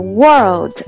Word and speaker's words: world: 这里面一定要注意那个 world 的读音啊， world 一定要有那个 L 的发音，因为这个单词的的它是把它略读的，所以它听起来就是 you could be the world。